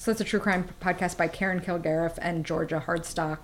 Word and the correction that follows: Hardstark: How do I say that? Hardstark.